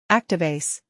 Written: Activase

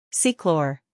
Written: Ceclor

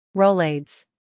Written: Rolaids

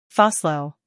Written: PhosLo

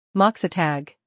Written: Moxatag

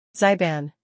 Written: Zyban

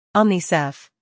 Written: Omnicef

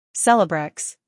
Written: Celebrex

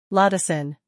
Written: Lodosyn

